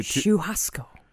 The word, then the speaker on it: Churrasco: Went to Churrasco. – Yeah, we went to... Oh, you pronounced it right. Mm-hmm. Very well done.